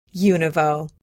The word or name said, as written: univo